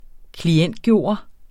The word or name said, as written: klientgjort